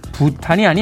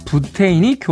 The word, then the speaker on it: 부테인: 그 결과 요드가 아닌 아이오딘, 부탄이 아닌 부테인이 교과서에 실렸기 때문이죠.